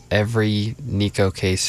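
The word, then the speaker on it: Neko Case: Every Nico Case fan would be roaring with applause right now, I'm sure, if we could hear them.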